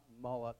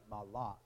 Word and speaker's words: malak: It's the word in Hebrew, malak, not malak as in the pagan god, but malak, M-A-L-A-K, in English, if you were to try to spell it in English.